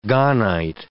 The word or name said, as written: gahnite